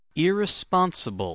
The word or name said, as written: irresponsible